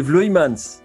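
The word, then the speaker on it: Erik Vloeimans: suis bien conscient que j'écorche son nom depuis le début de l'émission, donc si tu veux rétablir la bonne prononciation, vas-y. – C'est Vloimans. Merci.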